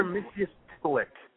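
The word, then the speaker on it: Mxyzptlk: Mr. Mitzius Titlick.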